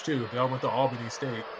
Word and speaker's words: Albany: They pronounce it Albany. Albany.